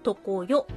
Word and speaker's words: 常世: 常世の国、これ常世の国漢字で書くと、常、そして世界の世。で、とこよと読むんですけども、意味としては変わることのない世界です。